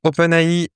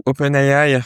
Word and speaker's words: OpenAI: Donc voilà pour OpenAI ou OpenAI, ça dépend comment on le prononce, si on le prononce à l'anglaise ou à la française. En général, c'est vrai que j'aime bien le prononcer plus dans la langue de l'entreprise, donc plutôt OpenAI.